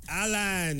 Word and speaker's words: Alan: Alan! Yes, that is my name. Alpha Lima, Alpha November. That's one of the ways to spell it.